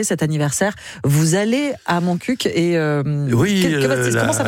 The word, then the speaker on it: Montcuq: Il faut prononcer le cul à la fin. Reportage. – Oui, comme ils disent, on dit un coq, on ne dit pas un co, donc on dit Montcuq.